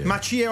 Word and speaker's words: Macie: Macie, occhio all'accento.